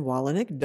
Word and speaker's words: Wolownick: D-I-E-R-D-R-E. That's Dierdre. And then Wolownick, W-O-L-O-W-N-I-C-K. I'm sorry, it's a toughie. – It is a tough one. – D-I-E-R-D-R-E. W-O-L-O-W-N-I-C-K.